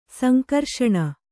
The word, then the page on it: ಸಂಘರ್ಷಣೆ: sangharṣaṇe